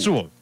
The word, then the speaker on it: Sewell: You just said it, Sewell. – Sewell, okay.